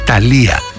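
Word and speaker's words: Thalía: Τα χειροκροτήματα δεν ήταν για το γεγονός αυτό, αλλά ήταν για μια σπουδαία μεξικάνα τραγουδίστρια, τη Θάλια, την οποία τη λένε Ταλία, γιατί δεν προφέρουν το Tough H που είναι θήτα.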